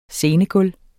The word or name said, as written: scenegulv